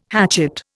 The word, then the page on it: hatchet: Indian